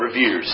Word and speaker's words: reveres: Okay, so by the way, I would pronounce that reveres. That's just one of those weird words that looks weird when it's written out.